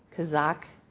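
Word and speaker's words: chazaq: It's pronounced kazak. It's C-H-A-Z-A-Q.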